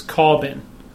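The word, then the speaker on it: Corbijn: And directed by Anton, we had a discussion about how you say his name, but I think it's Corbin. – It's got a J in there.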